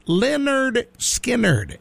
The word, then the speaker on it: Lynyrd Skynyrd: And it was titled, it's pronounced Lynyrd Skynyrd.